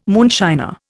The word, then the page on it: moonshiner: Indian